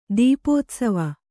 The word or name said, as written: ತೆಪ್ಪೋತ್ಸವ